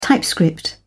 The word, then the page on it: typescript: British English